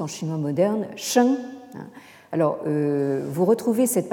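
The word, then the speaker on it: sheng (聖): Dans ce caractère qui se prononce en chinois moderne sheng, hein, au quatrième ton, et qui désigne donc le sage ou euh, la sagesse.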